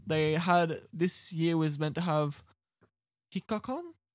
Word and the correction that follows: Hikakin: Oh, Hikakin.